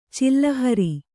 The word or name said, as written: ಚಿಲ್ಲಹರಿ